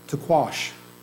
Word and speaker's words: taqsh: I'm probably going to mispronounce this, but I think if I pronounce it right, they're called taquash. T-A-Q-S-H. Taquash.